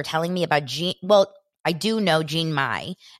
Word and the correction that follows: Jeannie Mai: Am I saying it right? Jeannie Mai, but I don't know her husband.